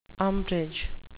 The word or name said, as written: umbrage